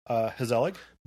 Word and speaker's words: Gezellig: Hezelig. – But it's spelled G-E-Z-E-L-L-I-G.